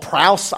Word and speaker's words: praus: it's a word called praus. I guess that's pronouncing it correctly. Praus, P-R-A-U-S, praus.